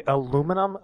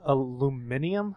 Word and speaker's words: aluminium: They say aluminum, aluminium? – Aluminium. – Aluminium. – They put they put like another I in there? – Well, yeah, because they have aluminum aluminances.